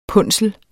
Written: punsel

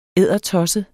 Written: eddertosset

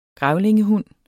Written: grævlingehund

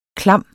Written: klam

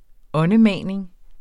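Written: åndemaning

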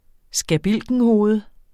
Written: skabilkenhoved

